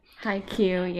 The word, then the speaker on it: Haikyuu: Haikyuu? Is that how you pronounce it? – Haikyuu, yeah.